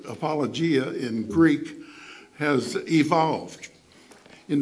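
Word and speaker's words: apologia (ἀπολογία): The Greek word there is I always mispronounce this word, apologio, or apologia. Apologia is better pronounced. Apologia. And it probably sounds very much like apology.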